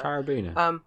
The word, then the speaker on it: carabiner: You know, you can attach your, is it carbiner? Is that how you pronounce it? The thingy, all the rock climbing people know I'm on about. – Carabiner. – Carabiner. Yeah.